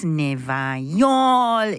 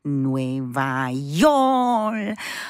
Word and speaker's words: Nueva York: Nueva York. In case you're wondering what that means, Nueva York is a phonetic Caribbean Spanish spelling and pronunciation of New York. Nueva York.